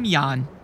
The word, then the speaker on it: Jan: I'm Jan. It's pronounced like yawn, but spelled with a J.